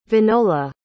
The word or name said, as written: winola